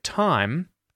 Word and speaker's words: thyme: This one comes from Hadaz, who asks, how do you pronounce the difference between the words time, T-I-M-E, and the word thyme, T-H-Y-M-E? So if you've just heard me pronounce both those words, time and time, you probably noticed that there is no difference in the pronunciation of these words.